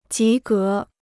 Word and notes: 及格: jí gé

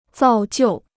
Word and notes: 造就: zào jiù

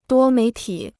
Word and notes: 多媒体: duō méi tǐ

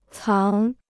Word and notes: 藏: cáng

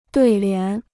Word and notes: 对联: duì lián

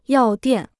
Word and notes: 药店: yào diàn

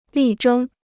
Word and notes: 力争: lì zhēng

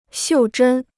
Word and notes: 袖珍: xiù zhēn

